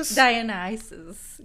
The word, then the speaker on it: Dionysus: You're just describing Dionysio. – Dionysus, yes, I didn't know how to say it.